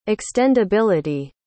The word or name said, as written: extendability